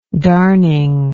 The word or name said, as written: darning